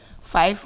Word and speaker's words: Fife: F-I-F-E. How do you pronounce that? – Fife, yeah, or Fife? – Fife or Fife. – Fife? – Fife or Fife. Fife. – I said Fife, Fife first.